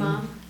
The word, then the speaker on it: imam: and I know the Amin. Is that how you say it? – Amin. Imam. – Amin? – Imam. – Imam. Okay.